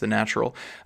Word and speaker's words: Natvral: The Natvral spelled N A T V R A L, so sorry for uh, potential mispronunciations aplenty on this particular pod episode, but I think that's how it's pronounced, The Natvral.